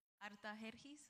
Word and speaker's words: Artajerjes: Pero Nehemías estaba en otra tierra y él era el copero para el rey, a ver si yo lo digo bien, Artajerjes, right? Artajerjes, that's how you say it, right? In English, Artaxerxes.